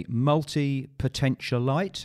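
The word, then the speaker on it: multipotentialite: Because you describe yourself as a multi-potentialite.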